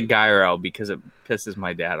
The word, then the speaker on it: gyro: We'd go out, get a gyro, or go out and have, like, you know, Wendy's, and you'd be having to use the bathroom. Yes, it's not pronounced gyro. That's an incorrect pronunciation. It's gyro.